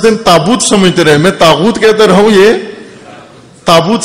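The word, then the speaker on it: طاغوت: ایک مجلس میں تاغوت, 10 دن تاغوت پر مجلس پڑی, تاغوت غین کے ساتھ.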